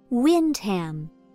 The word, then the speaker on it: Windham: Windham. It's pronounced like the words wind and ham.